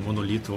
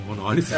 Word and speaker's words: monólito: Essa foi uma das controvérsias que apareceram nesse programa, a mais importante delas, a questão de se é monolito ou monólito. – É, basta consultar o Aurélio, está explicado lá, as duas pronúncias valem.